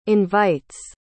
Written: invites

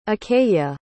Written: achaea

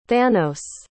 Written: thanos

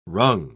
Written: rung

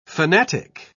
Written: fanatic

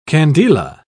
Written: candela

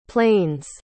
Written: plane's

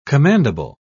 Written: commandable

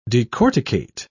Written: decorticate

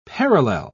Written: parallel

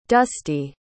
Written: dustee

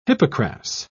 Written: hippocras

